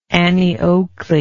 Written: annie oakley

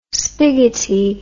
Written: spiggoty

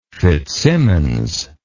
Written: fitzsimmons

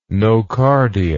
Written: nocardia

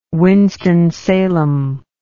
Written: Winston-Salem

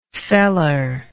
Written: feller

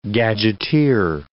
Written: gadgeteer

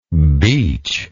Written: beechy

